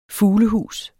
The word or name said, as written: fuglehus